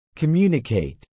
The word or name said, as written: communicate